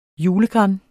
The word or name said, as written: julegran